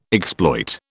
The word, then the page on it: exploit: British English